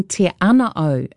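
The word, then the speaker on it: Te Anau: Humbly speaking, te ana o is correct pronunciation. Ana meaning cave.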